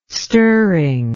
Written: stirring

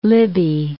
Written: Libby

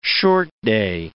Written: short-day

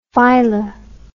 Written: fila

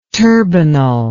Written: turbinal

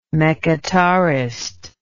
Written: mechitarist

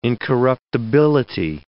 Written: incorruptibility

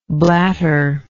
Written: blatterer

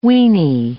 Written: weenie

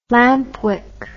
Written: lampwick

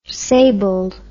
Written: sabled

